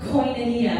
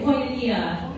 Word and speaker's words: koinonia: and it actually is a Greek word called koinonia. Everybody say koinonia. Koinonia. Yeah, it's not one of like a too terribly hard Greek word to pronounce.